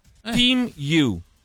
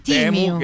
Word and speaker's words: Temu: Si pronuncia Team You. – Team You. – Con la I. Team You. Quindi non dite più Temu, che fate per ride se dite Temu.